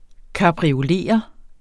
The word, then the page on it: kapriolere: kɑbʁioˈleˀʌ; kabʁi-